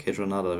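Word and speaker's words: Kaytranada: Kate Renada wird es ausgesprochen, ne? Ja.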